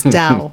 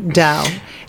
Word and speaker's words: Tao: She has a studio in Koreatown called Tao. Mm-hmm. I'm pronouncing it wrong. She would be upset with me. It's Dao. Dao. It's with a T, but you pronounce it Dao.